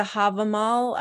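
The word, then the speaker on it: Hávamál: And maybe the Havamal, I don't even know if I'm pronouncing that correctly.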